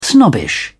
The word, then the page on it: snobbish: British English